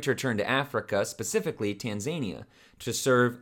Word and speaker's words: Tanzania: I always want to like start saying Taz from Looney Tunes when I think of Tanzania. I don't know why. Tazania. I want to pronounce it that way, but it's Tanzania. I said it the first time.